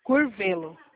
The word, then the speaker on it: Curvelo: Curvelo.